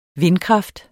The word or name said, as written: vindkraft